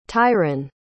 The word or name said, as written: tyrin